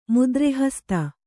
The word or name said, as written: ಮುದ್ರೆಹಸ್ತ